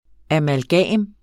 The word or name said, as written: amalgam